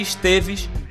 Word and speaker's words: Esteves: Indo então para o próximo bloco, a gente vai ouvir o Esteves, ou se você quiser procurar aí, é, se escreve STVZ, né, só as consoantes, de Esteves, com a música O Universo Observável da Janela.